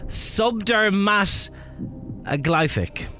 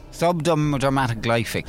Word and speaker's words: subdermatoglyphic: Your word is subdermatoglyphic. – Subdermatoglyphic. – Subdermatoglyphic. – Subdermatoglyphic. – S-U-B-D-E-R-M-A-T-O-G-L-Y-P-H-I-C.